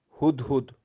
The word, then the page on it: huhhud: Kashmiri